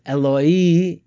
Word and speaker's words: Eloi: So Eli in Hebrew is my God. It's pronounced Eloi in Aramaic.